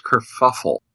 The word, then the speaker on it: kerfuffle: That was the big, I guess, kerfluffle, if that's my word of choice for today. And I believe it's pronounced kerfuffle.